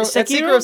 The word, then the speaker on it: Sekiro: Sekiro sounds actually right, though, the way that everybody's been saying it. But I have been watching videos on it. – Sekiro? – It's like Sekiro. It's S-E-K.